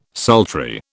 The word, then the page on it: sultry: British English